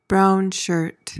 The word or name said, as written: brownshirt